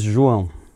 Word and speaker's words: João: So my name is João.